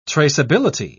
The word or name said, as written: traceability